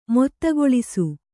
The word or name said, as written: ಮೊತ್ತಗೊಳಿಸು